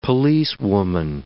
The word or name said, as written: policewoman